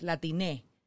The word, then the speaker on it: Latine: So the correct, quote-unquote correct term is Latine.